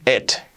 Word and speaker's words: it: Fijaos cómo lo digo, que no digo it, digo it, it. La I como una E, letra clave de nuestro método. It. No digáis it, será it.